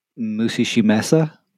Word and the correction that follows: Mushihime-sama: Mushihime-sama. – Mushihime-sama.